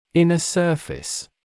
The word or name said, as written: inner surface